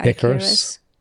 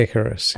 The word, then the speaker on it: Icarus: I think it's Icarus. The Greek guy, Icarus. – Icarus. Is it Icarus? – Yeah, Icarus. Yeah. – Okay. Icarus. Icarus and Daedalus. So Daedalus was the father and Icarus was the son.